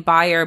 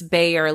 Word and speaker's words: Bayer: You know, people always pronounce my name Bayer, but it's Bayer, like bear, like the animal.